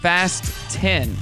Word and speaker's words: Fast X: And I mean, Fast X is meant to be pronounced Fast 10 because it's the 10th movie.